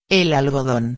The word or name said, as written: el algodon